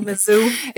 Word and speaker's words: Missouri: Christopher Gordon of the Missouri. – Oh, Missouri. – It's like now I'm mispronouncing words I actually know.